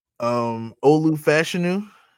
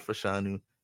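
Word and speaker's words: Olu Fashanu: Olufashinu. I found out that's how you pronounce it. It's not Fashanu.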